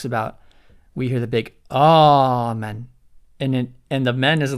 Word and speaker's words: amen: And the word amen, you also, when we say it in English, we might say amen and put this stress on the second syllable.